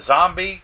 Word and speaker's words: Xombie: The Zombie rocket. – Just so you know, it's spelled differently. – Yes, it is. X-O-M-B-I-E.